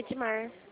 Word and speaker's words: Edma: Meu nome é Beatriz, eu gostaria de falar com o Edmar. – Edmar não existe, existe Edma.